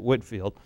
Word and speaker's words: Whitefield: Now, I do want to put up here George Whitefield again. Some people pronounce it Whitfield.